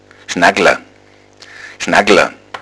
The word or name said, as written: schnaggla